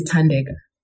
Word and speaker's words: Thandeka: My name is Thandeka.